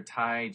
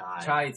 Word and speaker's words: chai: Stop drinking your Thai cheese. Is it Thai or Kai? – Chai tea.